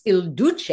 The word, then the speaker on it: Il Duce: And even Mussolini loved that name DOGE so much that he took on, it's pronounced Il Duce, as I understand it.